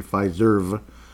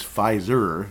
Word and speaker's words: Fiserv: Fiserv. I don't think it's Pfizer. It doesn't look like the same word. So, no, it's not. Pfizer, that would figure.